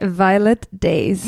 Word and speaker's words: Violet Days: Is that how you say it? Violet? – Violet Days. – Yeah, Violet Days. – Yeah, as long as you don't say violent, I'm good.